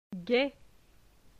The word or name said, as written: Gai